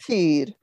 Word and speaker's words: fyr: Well, pardon my mediocre pronunciation, but FYR is a lighthouse in Norwegian. Is that correct? – That's correct. Yeah. – How do you pronounce that? – Fyr. Fyr. – Fyr.